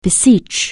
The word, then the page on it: beseech: anglais